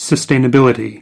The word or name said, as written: sustainability